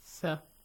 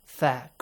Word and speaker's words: ث: ثم بعثناكم, two letter tha, they have to be pronounced tha. – Tha, correctly, yes? – Yes. – The way we say tha. Bite your tongue a little.